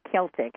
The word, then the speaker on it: Celtic: When you, when I was reviewing the history, I thought, my goodness, and I say Celtic, and I know it's it can be pronounced either way, Celtic or Celtic.